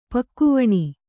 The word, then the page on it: ಪಕ್ಕಿವಣೆ: pakkivaṇe